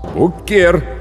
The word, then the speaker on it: Хукер: «Хукер», с ударением на «е» произнес новичок.